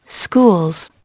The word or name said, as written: schools